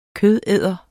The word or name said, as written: kødæder